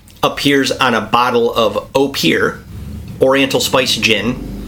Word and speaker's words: Opihr: Opir, but it's spelled O-P-I-H-R.